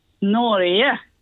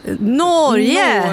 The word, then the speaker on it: Norge: Ett korrekt uttal. – Norge. – Underbart. Tusen tack. Ha en fin dag. – Tack samma. – Hej då. Ja, där fick vi det. Norge.